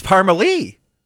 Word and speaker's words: Parmalee: Your Country K96, that was Parmalee. That's how they say it anyhow, with Gonna Love You.